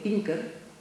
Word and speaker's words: Pinker: lo pronuncio como se escribe, Pinker,